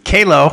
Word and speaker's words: Qalo: Kalo, which is spelled Q-A-L-O, is the name in silicone wedding rings.